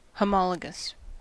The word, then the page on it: homologous: American English